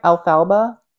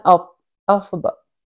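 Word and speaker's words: Elphaba: Am I saying that right? Alfalba? No, it's Alphaba.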